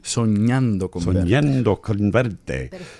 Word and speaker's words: Soñando con verte: Well, tell me if I'm pronouncing this correctly. Sonado Converte, is that right? – Soñando Converte.